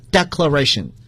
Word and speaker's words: declaration: 大家你注意，它的动词呢来自于 declare，你会发现动词和名词元音的读音是发生变化的。 declare， declaration，就像大家学过 explain， explanation 是一样的。